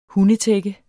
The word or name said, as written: hundetække